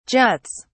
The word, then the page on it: juts: British English